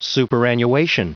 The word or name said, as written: superannuation